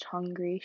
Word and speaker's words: Hungary: the mountains of Switzerland that included like Austria and hung it's pronounced Hungary, shush, I don't know what you're saying, Italy, France, and Spain.